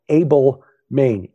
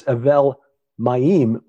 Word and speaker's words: Avel Maim: it looks like it's spelled Abel Main, A-B-E-L-M-A-I-N. You know, English, we'd read it and say, Abel Main. What is that? Well, Lipinski points out that means, in Hebrew, that's Avel Maim, which means waters of weeping.